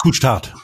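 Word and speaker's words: Couche-Tard: Couchtart, wie spricht man die aus? – Aber es ist Couchtart.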